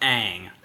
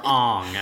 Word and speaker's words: Aang: So I would be an airbender, like Aang or Ong, as it's pronounced in the terrible movie.